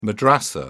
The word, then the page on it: madrasa: British English